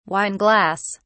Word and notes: wine glass: British English

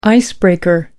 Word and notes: icebreaker: American English

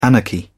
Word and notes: anarchy: British English

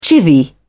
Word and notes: chivvy: British English

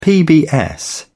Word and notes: pbs: British English

